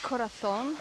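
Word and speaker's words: Corazón: Say Corazon? – I did. That's the pronounced. – That's the Spanish way, as opposed to the Latin American way.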